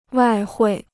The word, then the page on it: 外汇: wài huì